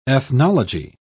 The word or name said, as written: ethnology